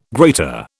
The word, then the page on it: grater: British English